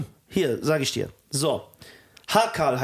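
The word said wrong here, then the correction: Hákarl: heißt das. Nee, Haukart wird das ausgesprochen.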